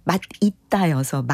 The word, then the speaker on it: 맛있다: 그게 사실 두개다 발음이 맞는데, 이제 맛있다가 원칙이지만 맛있다도 허용이 되는, 네, 그런 상황이, 예, 사실은, 네, 표준 발음법에 있기는 합니다.